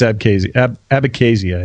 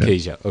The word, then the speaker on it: Abkhazia: I think it's Abkhazia, I think is how you say it. – Abkhazia, okay, Abkhazia.